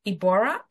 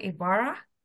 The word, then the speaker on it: Ybarra: So I want you to tune in as I welcome my special guest, Coach Paul Ybarra. Is that how you pronounce it? Ybarra? – You nailed it.